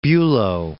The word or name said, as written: Bulow